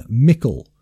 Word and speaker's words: Michael: In the native pronunciation of Michael, the CH was probably pronounced K. Michael would then have been Mickle, and so Mictus.